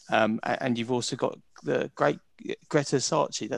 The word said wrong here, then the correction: Scacchi: That's how you say it, isn't it? Greta Sarchi. Yeah, Greta Scacchi.